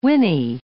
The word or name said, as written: Winnie